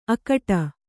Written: ಅಕಟ

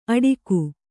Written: ಅಡಿಕು

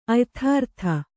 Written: ಅಯಥಾರ್ಥ

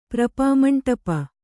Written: ಪ್ರಪಾಮಂಟಪ

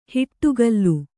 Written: ಹಿಡಿಗೋಲು

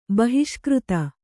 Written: ಬಹಿಷ್ಕೃತೆ

